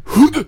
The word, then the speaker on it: Hood: Alors on n'a pas nos experts en anglais, donc on nous a dit aspire le H. C'est tout ce qu'on m'a dit. Moi, après, The Hood. Hood. – Voilà, j'essaierai de le prononcer comme ça, un peu comme coude, mais avec un C en moins.